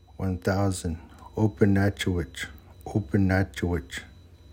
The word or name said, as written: sali7 open nacho7wech